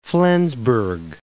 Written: Flensburg

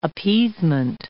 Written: appeasement

